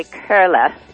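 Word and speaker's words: Koehle: It's getting close. It's actually Curla.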